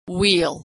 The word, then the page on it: wheel: /w/